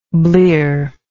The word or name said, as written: blearedness